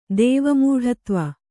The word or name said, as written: ದೇವಮೂಢತ್ವ